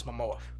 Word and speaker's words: Momoa: Juan and Beale, it stars Jason Momoa, am I saying that right? – Yes, Momoa. – Momoa as the title character with Amber Heard, Willem Dafoe.